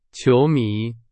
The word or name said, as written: 球迷